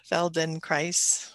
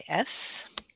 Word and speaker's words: Feldenkrais: Feldenkrais rhymes with paradise. So it's Feldenkrais.